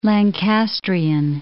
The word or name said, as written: Lancastrian